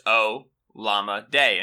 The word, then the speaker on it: Olamide: So his name is Alamide. Olamide. Mm-hmm. Right? But it's pronounced O. Llama Day, and his at on Twitter is Llama Day, like the word llama and the word day, which I think is tremendous.